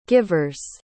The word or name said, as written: givers